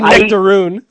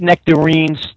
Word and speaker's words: nectarines: And then you said nectaroon. You even said it wrong. You said nectaroon. – I eat nectarines.